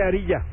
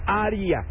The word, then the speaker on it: Ariya: Ariya. – No se dice Ariya, ya me corrigieron, se dice Ariya. – Ariya. Ay, ya ves, es Ariya.